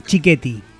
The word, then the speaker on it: Chiquetti: Mi apellido italiano, Chiquetti. – Sí. – Se pronuncia, está en castellanizado, claro.